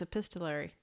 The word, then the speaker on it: epistolary: I just can't pronounce epistolary.